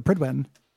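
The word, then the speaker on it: Prydwen: the Pridwin, which you said Pridewood earlier, which makes more sense. They pronounce it Pridwin, which is really strange.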